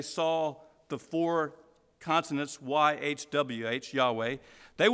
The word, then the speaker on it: Yahweh: And so as people over the years have tried to figure out how this name is pronounced, you know, most scholars think it was pronounced Yahweh, Y-H-W-H, the four consonants, Yahweh.